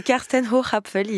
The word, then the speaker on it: Karsten Hochapfel: Ouais, bah à la française. C'est Karsten Hochapfel, il est allemand.